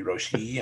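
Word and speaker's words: Roshe: It's supposed to be Roche-E, and I was calling it Roche.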